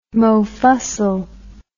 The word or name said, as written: mofussil